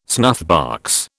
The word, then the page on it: snuffbox: British English